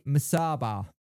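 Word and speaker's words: Musaba: Owls land Anthony Masaba. Have I said that right this time? – Yes. – Yes. Brilliant.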